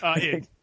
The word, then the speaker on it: id: I was going to say, do you pronounce it id or id? – Id. It's id.